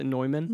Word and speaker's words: Neumann: Maybe I got a Neumann KM184. – Is that really how you say it? – Yeah. It's a Neumann.